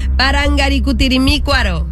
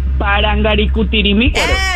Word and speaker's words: Parangacutirimícuaro: Ok, repite conmigo, Parangacutirimícuaro. – Vamos otra vez. – Te sigue faltando una sílaba. Parangacutirimícuaro. – Despacio, dilo despacio. – Parangacutirimícuaro.